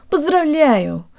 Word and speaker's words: поздравляю: guys, and today you'll learn how to pronounce the word поздравляю, or congratulations. Let's start with the last syllable. Ю is a diphthong made of two sounds, a Y sound and an U sound.